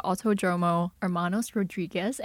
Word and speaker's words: Autódromo Hermanos Rodríguez: It was at the Autódromo Hermanos Rodríguez.